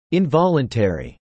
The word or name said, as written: involuntary